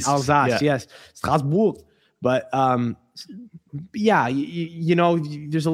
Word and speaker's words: Strasbourg: Strasbourg. Yeah. I've been there. – Right. Okay. But Strasbourg. – Yeah, I'm not perfect.